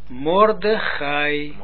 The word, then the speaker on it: Mordechai: Mordechai.